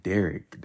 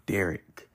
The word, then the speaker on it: Derrick: I'm Derrick. That's my name, Derrick. Remember that with two R's D E R R I C K.